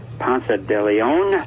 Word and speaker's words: Ponce de Leon: The last time we talked, explained to me if I pronounce this right, Ponce de Leon.